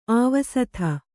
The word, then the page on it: ಆವಸಿತ: Kannada